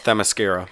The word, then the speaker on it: Themyscira: Themyscira.